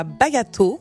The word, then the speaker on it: Bayato: Alors, il y a Bayato. – Oui. – J'ai bien prononcé. – Oui.